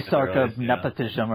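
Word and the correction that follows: Napishtim: Nepashtim. There's no other I. I don't know where you're adding the second I to, but yeah. It's Nepashtim.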